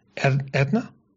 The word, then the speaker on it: Aetna: But if that's how you pronounce Aetna in America, then I'll go with Aetna. – Yeah, I used to work for them, so I know it's pronounced Aetna.